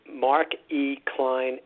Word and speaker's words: Mark E. Klein: It's Mark E. Klein, M.D. And I'll spell it M-A-R-K, middle initial E, K-L-E-I-N.